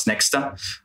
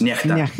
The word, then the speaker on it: NEXTA: And back then, I think the most popular Telegram channel that most of Belarusians knew, were aware of, was Nexta. Actually, it's pronounced Nechta, means someone in Belarusians, but the way it's spelled, it's people in the West call it Nexta.